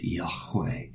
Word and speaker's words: Yahweh: The Hebrew word for the name of God is Yahweh. And we add the vowel sounds in. There's no vowel sounds in the original Hebrew. It's just four letters.